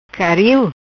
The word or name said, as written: Caril